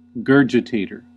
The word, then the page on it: gurgitator: uk: IPA: /ˈɡɜː.dʒɪ.teɪ.tə/; en-us: IPA: /ˈɡɝ.dʒə.teɪ.tɚ/